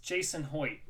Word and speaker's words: Jason Hoyt: I'm your host, and today I'm joined by Eric, my co-host, and we have a special guest, Jason Hoyt.